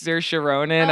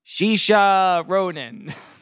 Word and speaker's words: Saoirse: It's just Dennis Quaid trying to pronounce Saoirse Ronan at the Golden Globes, and he goes, Shisha Ronan. – Not even close.